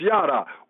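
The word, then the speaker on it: yadah: I believe it's pronounced yada.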